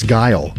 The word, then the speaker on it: Guile: It's Guile, like Kyle.